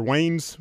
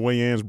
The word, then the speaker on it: Wayans: We'll show up and whether people mispronounce it, or it's either Wayne's Brothers, like there's one guy named Wayne and he has brothers, or the Wayans Brothers, but it actually started in 1985 with the two Wayne brothers, Eric and Keith.